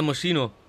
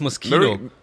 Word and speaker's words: Moschino: Ja, Moschino. Oh, das heißt Moschino.